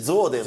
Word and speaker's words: Zuiderzee: Si dice Zoderzee. – Zoderzee. Zoderzee. Neanche un luganese riesce a pronunciarlo.